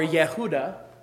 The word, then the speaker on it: Yehuda: And Judah, or Yehuda, is the actual Hebrew there. It sounds like the word praise, yada.